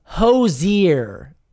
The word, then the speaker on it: Hozier: It's Quell says, Hozier is how you say it.